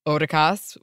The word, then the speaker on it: Otakas: Otakas is the best, and Kim put in a pronunciation note, pronounced Otakas with a long O like in show, so thank you.